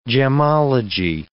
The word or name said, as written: gemmology